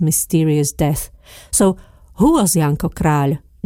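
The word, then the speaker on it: Janko Kráľ: Try to say Janko Král. Many Slovaks pronounce the surname of the romantic poet incorrectly. Král is not the same as Král, which means king in Slovak.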